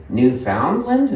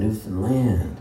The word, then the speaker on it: Newfoundland: And they always say, "Newfoundland?" And I go, "No, it rhymes with understand, Newfoundland."